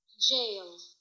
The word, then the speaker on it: gaol: It is the word jail, right? But it is spelled G-A-O-L, so it looks like gaolers, but I think it might even be pronounced jailers.